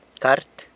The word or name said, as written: cart